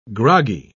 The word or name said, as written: groggy